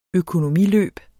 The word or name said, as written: økonomiløb